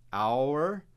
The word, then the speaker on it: our: Mas, de fato, no dia a dia, nós praticamente nunca pronunciamos o nosso our, O-U-R, desta forma. Nós pronunciamos reduzido com praticamente o mesmo som do verbo to be. Are, A-R-E, né? Eu sou, I am, você é, you are. Então, com esse som aí, are.